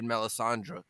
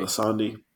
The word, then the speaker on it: Melisandre: I just love the look on her face, dude, as she was snarling as she turned away as after they beheaded Melisandre. Did I say her name right? – Melisandre.